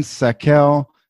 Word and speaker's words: Sakell: Today, my guests are Ethan Sakel and Sean Tyner.